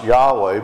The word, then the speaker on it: Yahweh: One of them, we believe the letters YHWH, which is sometimes pronounced Yahweh, but actually we shouldn't even say it because the Jews never pronounced the name,